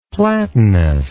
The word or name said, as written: platinous